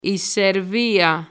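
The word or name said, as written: Σερβία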